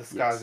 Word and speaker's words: Skaze: Okay, so they're called either Skaze or Skaze. It's spelled S-K-A-Z-E. – I would definitely say Skaze. Skaze.